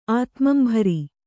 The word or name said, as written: ಆತ್ಮಂಭರಿ